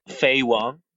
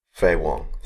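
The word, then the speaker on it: Faye Wong: And then we have Fei Wong, if I pronounce that at all correctly. – Fei Wong. – Fei Wong, thank you.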